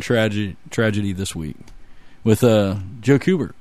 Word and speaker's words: Kubert: Kubert.